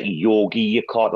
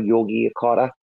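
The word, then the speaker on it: Yogyakarta: It starts with like a G, but it's pronounced like Yogi Yakarta, like Yogi Bear, like Yogi Yakarta.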